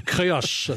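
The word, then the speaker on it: Créac'h: Le plus célèbre c'est celui du, je ne sais pas comment on prononce ça, le Créarch. – Vous le prononcez très bien, c'est le phare du Créarch, C-R-E-A-C'H, qui est le phare le plus puissant d'Europe.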